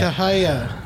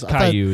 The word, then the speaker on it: Caillou: Kahaya. Kahaya. Well, I don't know what that is. – Caillou is how that's pronounced.